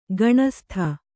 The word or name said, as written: ಘನಸ್ತ